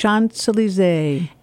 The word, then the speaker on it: Champs-Élysées: Champs-Élysées.